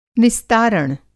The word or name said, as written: निस्तारण